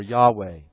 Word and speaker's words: Yahweh: Jehovah or Yahweh, depending on how you pronounce, which scholar you talk to, how you pronounce that four-letter word of God, that four-letter name of God.